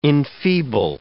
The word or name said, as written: enfeeble